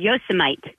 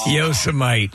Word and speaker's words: Yosemite: And I had to read an article about a park called Yosemite. – Yes. – Yosemite.